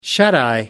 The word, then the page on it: shuteye: American English